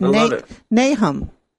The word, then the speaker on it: Nahum: But it was Nahum. – Okay, so Nahum 1:7.